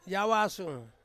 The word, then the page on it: awasung: Ansus